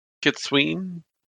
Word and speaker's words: Kitsune: Also, you'll hear it pronounced a lot of different ways. Kitsune is what I've always called Ketsune. What was the one you were talking about? – I heard Kitsween.